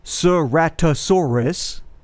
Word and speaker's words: Ceratosaurus: You pronounce their name Ceratosaurus.